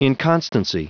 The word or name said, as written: inconstancy